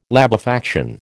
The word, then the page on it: labefaction: American English